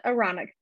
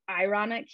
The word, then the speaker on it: Aaronic: Yes, so he has the Aaronic priesthood. They pronounce it ironic here a lot, like people just mispronounce it, and I always laugh and I tease my husband.